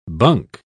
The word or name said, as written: bunk